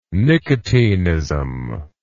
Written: nicotinism

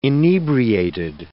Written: inebriated

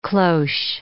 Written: cloche

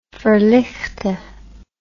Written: verligte

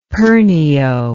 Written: pernio

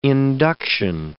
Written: induction

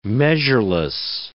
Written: measureless

